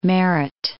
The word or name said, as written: Merritt Island